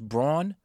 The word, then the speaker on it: Braun: His name is pronounced Braun, but it's Christian Brown.